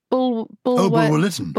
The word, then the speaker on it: Bulwer-Lytton: Edward Bulwer-Lytton.